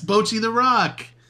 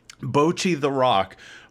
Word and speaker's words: Bocchi the Rock: That was reserved for what, and I don't know if I'm saying this right, Baki the Raw? – I think it's pronounced Roke. – Roke? – Yeah. – Baki the Roke? – It's Bochy the Rock.